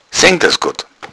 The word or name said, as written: sengdasgodd